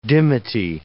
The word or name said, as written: dimity